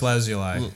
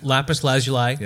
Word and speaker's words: lapis lazuli: I bought a lapis lazuli. Is that how it's pronounced? – Lapis lazuli. – Lapis lazuli. – Yeah.